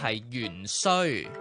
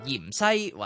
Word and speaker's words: 芫荽: 后来翻查字典先发现，原来正统的读法应该是芫荽。不过因为读音不好意头咧，大家先读咗做盐西或者是芫西。